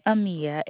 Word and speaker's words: Amia: Amia, A-M-I-A.